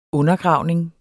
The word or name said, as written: undergravning